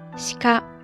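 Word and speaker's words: しか: 鹿在日语当中读作西卡，西卡，西卡。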